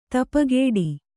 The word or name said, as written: ಧೂಪಘಟ